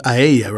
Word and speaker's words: Aeya: Hey, congratulations to you because you mentioned on your most recent album, and I believe you pronounced it Aeya, right? – Yeah, okay.